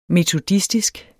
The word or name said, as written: metodistisk